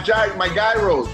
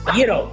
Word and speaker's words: gyro: Souvlaki, that's what I used to make my gyros, right? – Gyro, it's not gyro, it's gyro. Greece does not have a G, so it's gyro.